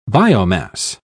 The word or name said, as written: biomass